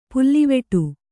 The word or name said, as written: ಪುಲ್ಲಿವೆಟು